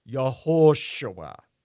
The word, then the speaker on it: Yehoshua: Yehoshua,